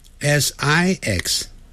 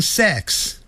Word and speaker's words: six: 但他不会念成 five five five five 六 s i x six it 短的元音 six six，很多人会念成 sex， sex 是性，性别那个性，好 sex 那是 s e x，所以六啊要念成 six six